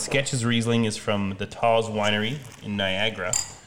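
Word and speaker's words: Tawse: From Taz or Taze. I'm not sure how you say it. – It's Canadian. It's Taze.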